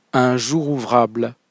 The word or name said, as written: un jour ouvrable